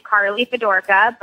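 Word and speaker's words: Carleigh Fedorka: My email is my first and last name, Carly Fedorka, but it's spelled kind of funny.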